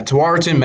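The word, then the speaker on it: Tewaaraton: And then on March 11th, he was named the 2021, how do you say that, Tawaritan? – Yeah, Tawaritan men's watch list.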